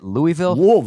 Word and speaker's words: Louisville: Is that how you say it? Louisville. – That's how you should say it. Louisville.